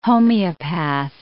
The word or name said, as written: homeopath